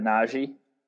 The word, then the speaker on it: Nnaji: They also draft Zeke Nnaji. Am I saying that right? – You got it, Nnaji.